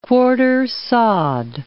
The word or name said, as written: quartersawed